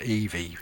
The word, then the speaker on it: Evie: This is called, well, it's called Eve, but he pronounces it Evie.